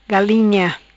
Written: Galinha

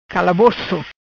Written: Calaboco

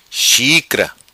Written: Xicra

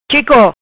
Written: Chico

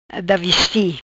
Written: Da vesti

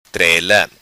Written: Trela

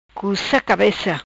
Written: Cuca Cabeca

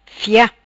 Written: Fia

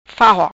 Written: Fahoc

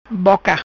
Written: Boca

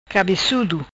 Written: Cabecudo

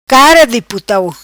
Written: Putau Cara Di

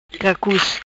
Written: Cacus

